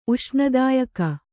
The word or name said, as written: ಉಷ್ಣದಾಯಕ